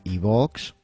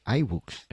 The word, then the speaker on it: iBooks: Pero si, lo quieres pronunciar en inglés, la I es una I y las dos O's son una U. Entonces, iBooks.